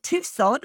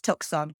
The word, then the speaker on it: Tucson: At his first casting as an American, he actually thought Tucson was pronounced Tucson, which, to be fair, so did I for a very long time until I realised that it's not pronounced phonetically.